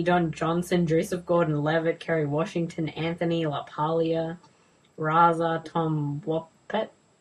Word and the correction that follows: RZA: RZA, right? Pronounce things differently.